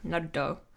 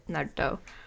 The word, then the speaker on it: Naruto: Naruto. That's how you say it. Naruto.